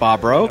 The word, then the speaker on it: Bobrow: Scott Bobro. – Bobro. – Very close. – Bobro. Bobro. – Lean as heavily towards the French as you can.